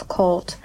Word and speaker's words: Karuto: Karuto, or pronounced as Colt,